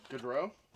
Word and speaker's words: Gaudreau: I had Matthews, Wheeler, McDavid, Anderson, Thornton, and Gaudreau.